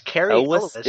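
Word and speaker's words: Cary Elwes: is it Carrie Yule? Like... – Elvis, Carrie Elvis. – It is Elvis.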